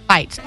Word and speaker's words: Fite: Feitz. F-I-T-E. Oh. Mrs. Feitz, first grade class. – I was picturing everybody gets in there and they just fight. – No, it's pronounced like fight.